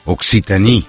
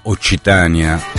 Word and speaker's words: Occitanie: come pronunciano i francesi l'Occitania, Occitan, Occitanie. Con la C che noi diamo per dolce, quindi chiamiamo Occitania, è un po' più chiuso invece in Languedoc e non già in Languedoc, Occitanie.